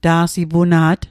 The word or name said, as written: ta'sipuna't